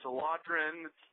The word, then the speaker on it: Eladrin: So it's Genasi, and it's Eladrin, and it's Ominator, and I can't think of any other controversial pronunciations.